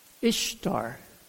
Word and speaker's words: Ishtar: Which is pronounced Ishtar.